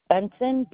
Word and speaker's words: Benson: Benson, B-E-N-S-O-N.